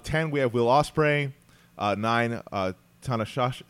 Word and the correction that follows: Tanahashi: Uh, I'm sorry, I butchered that one. Tana Tanahashi. Tanahashi.